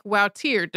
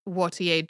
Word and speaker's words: Wautier: These paintings turned out to be by 17th century Flemish painter Michelina Wautier, who you will also hear pronounced more like Wautier, depending on whether the person is using a French pronunciation or a Dutch one.